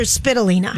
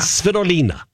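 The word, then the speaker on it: Svitolina: Donnie pronounced her name completely different. Yeah. I called her Spitalina. – Yeah, something. – No, I don't think so. – It's Spitalina. – Yeah. – Svetolina.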